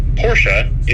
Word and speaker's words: Porsche: And the reason that the name is pronounced that way is that Porsche is a German company, and in the German language, oftentimes vowels at the end of words, particularly E's, are pronounced. So Porsche is the correct pronunciation of the name of the company and also the last name of the founder.